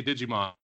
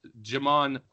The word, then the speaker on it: Djimon: Now I usually say Digimon, but that's not how you pronounce his name. It's Djimon Hounsou.